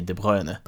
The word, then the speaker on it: De Bruyne: Där jag alltså väljer att säga De Bruyne bara för att jag har hört i någon intervju att han säger De Bruyne.